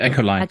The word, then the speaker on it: Acolyte: Aber er sagt selber zu mir bei Akolyt, also wie spricht man es richtig aus? – Der Acolyte. – Acolyte, ja.